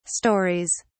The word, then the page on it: stories: British English